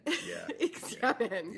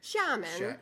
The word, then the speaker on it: Xiamen: Xiamen. – Yeah. – Xiamen.